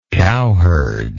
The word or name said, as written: cowherd